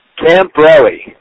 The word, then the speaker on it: Camporelli: And I know that was three syllables instead of two. How do you pronounce your name, Matthew? – Camporelli.